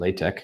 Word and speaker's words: LaTeX: I pronounce it LaTeX because that's the typesetting system I use to produce research papers.